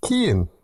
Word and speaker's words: Cian: It's pronounced Kian, like key in the door, but without the door part.